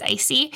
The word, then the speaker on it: ICSI: I think is what it's called, I C S I, but I think it's pronounced icy.